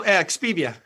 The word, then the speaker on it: Expivia: He is the CEO of am I pronouncing that right? – Yeah, Expivia? No, that's good. Expivia.